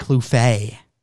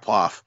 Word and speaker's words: Plouffe: It is Brock Plouf. There's a couple of pronunciations of it because it's French, but Plouf is the Americanized version. And the other one's like Plouf.